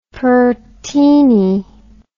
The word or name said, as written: Pertini